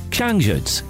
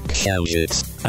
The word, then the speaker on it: Księżyc: Księżyc. – Księżyc.